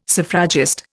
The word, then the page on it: suffragist: Indian